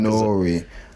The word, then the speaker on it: N.O.R.E.: Is that how you say it? N-O-R-E? You're supposed to say Nori? I've never said it out loud. – I'm Nori.